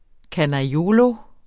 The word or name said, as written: canaiolo